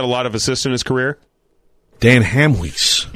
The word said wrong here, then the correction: Hamhuis: I seriously still can't say his name right. So all last year, I'm like, I'm just not going to say it. Dan Hamhuis. – That's good enough.